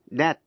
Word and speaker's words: debt: A-C-C-U-R-A-C-Y, accuracy. Và precision, P-R-E-C-I-S-I-O-N. Cũng ôn lại những chữ dễ phát âm sai. như là chữ key, Q-U-A-Y, nghĩa là bến tàu. Debt, D-E-B-T, nghĩa là nợ. Và receipt, R-E-C-E-I-P-T, là biên lai.